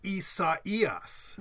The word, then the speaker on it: Isaias: No, it was the Spanish. To interrupt you there, I'm sorry, but it was Spanish. They it's the Spanish pronunciation. – Oh, okay. So then I have no idea, but it's pronounced like, kind of like acai, right? I mean, like, we were talking about that the other day about foods. – No, it's actually isaias.